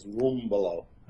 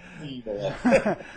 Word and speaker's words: Rumbolo: Well, the real Italian way is Rumbolo. – Oh, forget it. Well, I can do that. Rumbolo, all right. – Rumbolo.